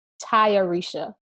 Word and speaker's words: Tayarisha: So it's pronounced, at least from what I saw in this interview, Tayarisha Poe. I hope that's correct.